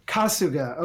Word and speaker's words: Kasuga: Kasuga.